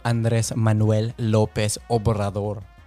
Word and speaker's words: Andrés Manuel López Obrador: But it's Señor Andrés Manuel López Obrador.